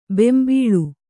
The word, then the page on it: ಬೊಂಬುಳಿ: bombuḷi